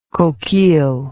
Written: coquille

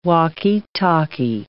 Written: walkie-talkie

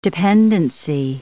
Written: dependency

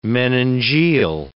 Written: meningeal

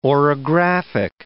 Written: orographic

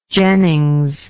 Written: Jennings

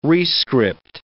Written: rescript

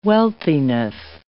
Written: wealthiness

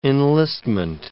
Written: enlistment

